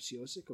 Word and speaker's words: Ciosek: We've been talking to Bob Ciosek of World's Greatest Team, and you said it right again, nice twice. Open and close.